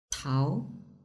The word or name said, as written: tao